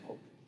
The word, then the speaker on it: Koch: Now, Robert Koch. Some people pronounce it Koch. Uh, he's German. His he is important.